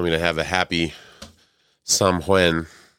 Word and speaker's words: Samhain: How was your Samhain, if I'm pronouncing that right? Probably not. – Samhain. – Samhain. – Samhain. – Samhain. Okay.